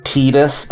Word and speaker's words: Tidus: You remember how when they later added voice acting to a bunch of stuff, they started trying to get us to say his name was Tidus, and we were like, no. – That is how it's pronounced.